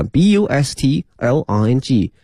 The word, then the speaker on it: bustling: 夹在这些高楼大厦之间，图书馆哈，后面用的是 city bustling 哈，这个 bustling 哈，非常熙熙攘攘哈，特别繁忙这个词 b u s t l i n g，同学们注意一下，这个 t 呢是不发音的 bustling 哈，陆家嘴 financial hub，这里面 h u b 呢是指一个中心哈，一个枢纽哈 financial hub。